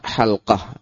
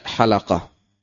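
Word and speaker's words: حلقة: Ya, jadi pengucapan yang benar, halqah. Ya, sesuai bahasa Arab, halqah. Bukan halaqah. Ya, halqah.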